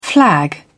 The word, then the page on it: flag: British English